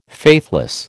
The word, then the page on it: faithless: American English